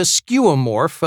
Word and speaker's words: skeuomorph: It's spelled S-K-E-U-O-M-O-R-P-H.